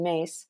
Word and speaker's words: Myss: I was at a workshop with Carolyn Mace, the author, the well-known author of Anatomy of the Spirit and so many other books since then. Mace is spelled M-Y-S-S, but pronounced mace like corn mace.